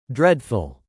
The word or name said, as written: dreadful